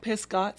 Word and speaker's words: Pisgah: Pisgah? – You did, absolutely.